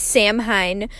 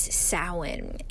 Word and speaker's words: Samhain: Now, Samhain does not sound at all as it's spelled. It actually looks like it's pronounced Samhain, but the correct, or one of the main pronunciations of the holiday, is Samhain.